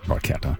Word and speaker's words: Barchetta: I think that in the song they may say Barchetta, but it's pronounced Barchetta.